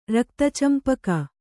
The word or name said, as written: ರಕ್ತಚಂಪಕ